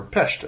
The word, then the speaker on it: Pest: Like, just for example, if you we traveled once to Budapest, so underratedly, Budapest is two cities, Buda and Pest, so, or Pest, as they pronounce it.